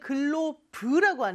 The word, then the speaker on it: glove: Glove, 근데 우리는 glove라고 하는데 저 V 발음 주의해서 glove라고 해주시면 돼요.